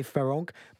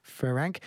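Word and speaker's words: Farrenc: It's easy to say Ferenc, but actually, apparently, it is Louise Ferenc.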